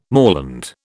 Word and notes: moorland: British English

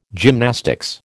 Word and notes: gymnastics: American English

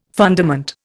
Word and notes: fundament: Indian

